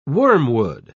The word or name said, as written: wormwood